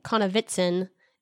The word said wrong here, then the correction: Konovnitsyn: and that's another syllable in there. Is that right? Konovitsyn? Konovnitsyn. There's an N.